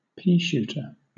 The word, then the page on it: peashooter: British English